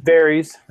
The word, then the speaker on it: Varys: Varys.